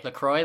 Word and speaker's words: LaCroix: LaCroix, like the drink.